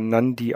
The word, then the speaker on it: Nadi: Das wird Nadi geschrieben, aber Nandi ausgesprochen.